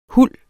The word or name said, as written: huld